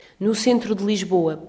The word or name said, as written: no centro de Lisboa